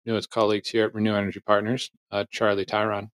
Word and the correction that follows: Tryon: or Tryon. Also, Charlie, let's get that out of the way. How do you say your last name? – Yeah, it's Tryon, but I,